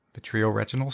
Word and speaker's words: vitreoretinal: Vitreoretinal surgeon, professor of ophthalmology at the Medical College of Wisconsin, Milwaukee.